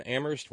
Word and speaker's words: Amherst: And so, consequently, we have thanks to send out to Shorty in L.A., to our friends at Home Dirts, to Terry in Amherst, where only the H is silent,